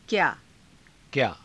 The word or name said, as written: kyaa